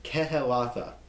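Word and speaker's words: Kehelathah: See how you pronounce everything there? Kehelatha.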